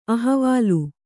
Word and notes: ಅಹವಾಲ್: ahavāl; kannada

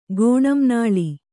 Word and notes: ಗೋಣಂನಾಳಿ: kannada

